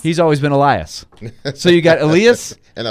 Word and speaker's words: Elias: It's spelled E-L-I-A-S. He says, if you're, he uses more of the Swedish pronunciation, Elias.